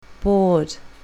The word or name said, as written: bored